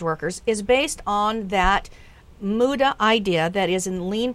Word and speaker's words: muda: It was called MUDA. Tell us am I pronouncing it correctly? – You are.